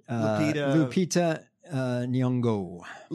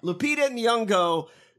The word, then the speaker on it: Lupita Nyong'o: Lupita Nyong'o. – Lupita Nyong'o, great actor.